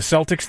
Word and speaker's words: Celtic: The Celtic is the proper pronunciation from Ireland. So when people started seeing it in America, and because all of the English words had the soft C like cedar or, you know, along those lines, that's why they started calling it Celtic, even though it should be Celtic.